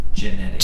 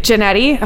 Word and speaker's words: Jannetty: Jannetty. – Jannetty.